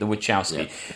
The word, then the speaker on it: Wachowski: Wachowski.